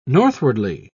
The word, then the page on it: northwardly: British English